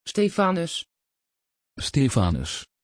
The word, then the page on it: Stefanus: Dutch